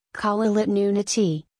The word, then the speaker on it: Kalaallit Nunaat: Greenland, Greenlandic, Kalaallit Nunaat, pronounced Kalaallit Nunaati,